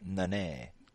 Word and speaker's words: Nunes: Is it pronounced Nunes? – People made it a meme, so I know about Nunes. – It. – Nunes. Nunes. – That's Nunes.